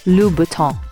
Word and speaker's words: Louboutin: Christian Louboutin is often pronounced as Christian Louboutin, but it's actually Christian Louboutin, and easy on the N.